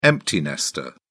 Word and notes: empty nester: British English

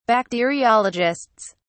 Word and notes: bacteriologists: British English

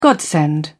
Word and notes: godsend: British English

